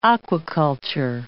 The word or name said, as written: aquiculture